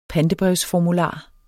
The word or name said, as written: pantebrevsformular